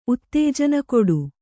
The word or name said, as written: ಉತ್ತೇಜನಕೊಡು